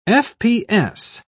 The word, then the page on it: fps: British English